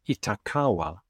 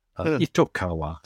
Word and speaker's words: Itokawa: from the asteroid Itokawa. Itokawa, sorry, mispronunciation there.